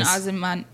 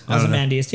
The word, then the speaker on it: Ozymandias: Ozymandias. – Yeah, whatever his fucking name is. – Ozymandias.